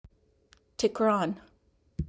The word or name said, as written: Tikran